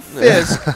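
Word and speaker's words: Fiske: His name is spelled with an E, but it's silent. It's Braden Fisk, Florida State University.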